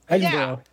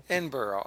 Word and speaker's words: Edinburgh: Edinburgh. – Yeah. Edinburgh.